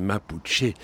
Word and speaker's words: Mapuche: Hein, c'est Mapuche, la vraie prononciation.